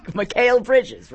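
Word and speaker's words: Mikal Bridges: McHale Bridges, right? Isn't that how you pronounce it? – Yeah, I don't know why they traded everybody for that guy.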